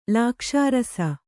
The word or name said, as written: ಲಾಕ್ಷಾರಸ